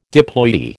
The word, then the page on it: diploidy: American English